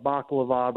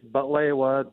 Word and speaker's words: baklava: A lot of people call it baklava, but we had a big argument last year, you and me, Jeff, about how to pronounce it. It's butlewa, the Arabic way.